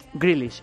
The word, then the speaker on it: Grealish: Grealish, ¿lo he dicho bien? – Grealish. – Grealish. – Sí.